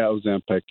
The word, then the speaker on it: Ozempic: Did you say Ozempic? – Yeah, Ozempic.